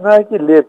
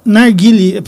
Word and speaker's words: narguilé: Narguile, pelo menos eu conheço por narguile. – Tem um acento agudo. Se tiver acento agudo em francês, você tem que pronunciar aí como fechado, narguilé.